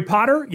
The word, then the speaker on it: Potter: My name is Jerry Potter. Yes, it rhymes with the boy wizard, host of the Social Media Marketing Talk Show, a production of Social Media Examiner.